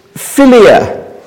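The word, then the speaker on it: Philia (φιλία): And so there's philia.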